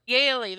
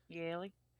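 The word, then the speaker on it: Yeley: Yealey, that's what it is. Oh, so it's like Yale, like Yealey. Yaley. Like, I'm a Yaley. Woohoo. I go to Yale.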